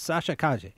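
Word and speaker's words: Sasha Calle: And that's how she pronounces it. I went and looked. I checked. She pronounces it Sasha Kaye.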